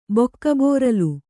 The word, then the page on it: ಬಕ್ಕಬೋರಲು: kannada